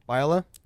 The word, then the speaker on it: Biola: That's how you pronounce it, right? Biola? – Yes.